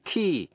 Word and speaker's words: quay: A-C-C-U-R-A-C-Y, accuracy. Và precision, P-R-E-C-I-S-I-O-N. Cũng ôn lại những chữ dễ phát âm sai. như là chữ key, Q-U-A-Y, nghĩa là bến tàu. Debt, D-E-B-T, nghĩa là nợ. Và receipt, R-E-C-E-I-P-T, là biên lai.